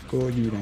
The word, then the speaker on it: Gojira: Y mejor opción Gojira, como, que es como se pronuncia en japonés. – Gojira en japonés.